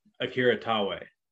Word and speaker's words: Akira Taue: That guy, the last one, how do you pronounce his name? – Akira Taue.